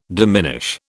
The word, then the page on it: diminish: British English